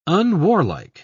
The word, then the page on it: unwarlike: British English